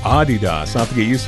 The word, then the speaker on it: Adidas: Did you know that most of us pronounce Adidas wrong? It's actually supposed to be Adidas. – Really? – Yeah, slight emphasis on the first syllable, not Adidas. – Adidas. All right. – Yeah, it's German.